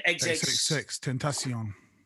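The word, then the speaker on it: XXXTentacion: XXX, Tentacion.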